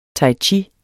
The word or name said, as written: chi